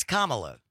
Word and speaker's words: Kamala: No, no, no, they're not. It's Kamala. – There's two ways to pronounce the name. There's one correct way for Kamala Harris.